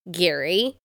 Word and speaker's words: Gerry: Okay, so his name is spelled G-E-R-R-Y, but it's pronounced Gary.